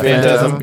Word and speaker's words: Phantasm: Am I saying that right? – Yeah, the Phantasm. Yeah, okay.